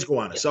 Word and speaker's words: Gowanus: Is it, you know, I never know how to say it. Gowanus? How do you guys say it? – Yeah. – It is Gowanus.